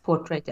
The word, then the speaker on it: Self Portrait: NK så hittade jag eh, ett märke som heter Self, jag kan ju inte uttala Self Portrait Porträtt? – Portrait ja.